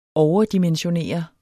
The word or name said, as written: overdimensionere